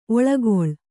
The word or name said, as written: ಒಳ್ಗಾಲ